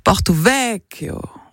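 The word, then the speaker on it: Porto-Vecchio: Voilà, apparemment moi non plus, parce que notre standardiste Saveria, d'origine corse, vient de me reprendre sur la prononciation. Alors il faut dire Porto Vecchio.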